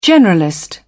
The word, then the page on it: generalist: British English